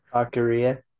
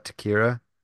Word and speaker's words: Taqueria: Taqueria. – Taqueria, what did I call it? Takira? – Yeah.